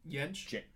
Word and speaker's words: Jentsch: Jentsch? – It's Jentsch? – Yeah, Jentsch.